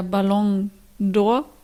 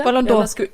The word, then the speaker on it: Ballon d'Or: Es war jetzt der Ballon d'Or. Hab ich es richtig ausgesprochen? – Super. Mhm. Genau, ein bisschen schneller, ein bisschen flüssiger. – Ballon d'Or.